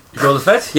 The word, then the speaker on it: Jour de Fête: Jour de Fête, yep, is... – He's so proud of himself.